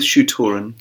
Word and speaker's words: Shu-Torun: The Shu Toran War. – Ooh, how do you spell that? – SHU-TORUN. – Oh, okay. – So I'm going with Shu Toran.